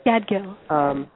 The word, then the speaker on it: Gadgil: I don't know if I'm pronouncing his name correctly. – That's right, yeah, Ashok Gadgil. – Gadgil? Or Gadgil.